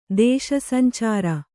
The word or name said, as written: ದೇಶಸಂಚಾರ